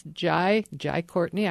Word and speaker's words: Jai Courtney: Here he goes to Russia, and he's got a son there, and the young actor's name is pronounced Jai Courtney.